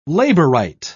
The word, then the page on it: labourite: British English